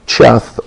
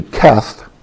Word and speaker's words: Cheth: Look at verse 57 at Cheth, or actually Keth, and it's Keth because you got that little line in the English pronunciation, help underneath the CH tells you it's a hard K sound.